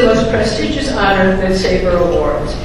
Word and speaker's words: SABR: In 1996, the Society, known by its acronym S-A-B-R, pronounced SABR,